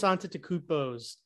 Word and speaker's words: Antetokounmpo: Like Giannis Antetokounmpo's fucking reach, dude.